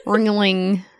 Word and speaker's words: Ringling: The Ringley brothers. – Ancestors. Did you say it correctly? – Saturnites. – Ringling brothers? She said. – Ringling. Didn't we teach you this? – Yes.